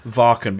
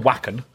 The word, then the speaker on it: Wacken: But oh, you called it Varken? – Varken, because I'm a metal elitist and that's what the locals call it. but I've done Wacken.